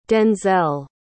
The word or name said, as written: denzel